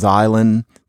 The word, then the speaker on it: island: But that meant that some boobo decided that we needed to have the S in the word island, even though we weren't pronouncing it, in order to tip our cap to the word's supposed origins in French.